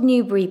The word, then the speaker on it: Newbury: It's called Newbury Park. I say it wrong. I know that because people always correct me. You have to make the bury bit sound like bree, but I keep forgetting.